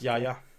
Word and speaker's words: Yahya: That's just how it is pronounced. It's Yahya.